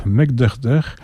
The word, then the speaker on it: Mek'dr'dr: donc c'est le groupe Mekderder. Enfin, ça s'écrit MEK'DR'DR.